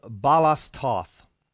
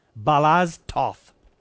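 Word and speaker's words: Balázs Tóth: Denver police, 2 weeks ago, destroyed the vehicle of 37-year-old Balas Toth. I don't know why it is that people associated with these bizarre stories always have weird names. This guy's name is spelled B-A-L-A-Z-S-T-O-T-H. Balas Toth.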